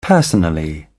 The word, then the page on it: personally: British English